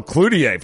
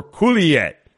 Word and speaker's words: Coulier: Saget, Stamos, or Coulier? That's the name of the game, okay? – Oh, okay. – Am I saying his name correctly? Stanzig and I were. Coulier is correct.